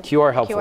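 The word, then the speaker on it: QRHELPFUL: So the program, if I'm saying it right, QR helpful, is that how? – QR helpful. QR helpful. Cryptonym, yes.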